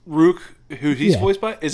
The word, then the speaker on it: Rukh: it's the way it's spelled is R-U-K-H. So you can't even say Rook, it's just Rook. You gotta have like a little bit of gargle behind you.